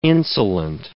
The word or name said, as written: insulant